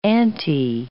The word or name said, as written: anti